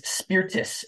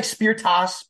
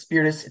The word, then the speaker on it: Spirtas: It is Spirtas. It looks like Spirtas, but it's Spirtas.